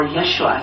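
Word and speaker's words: Yeshua (ישוע): In the birth of the Christ child, God tells Mary through the angel that the baby is to be named Jesus, or Yeshua as it's pronounced in Hebrew, which is actually the same as the name in English we say Joshua.The name's meaning is the Lord is salvation, or more simply, God saves.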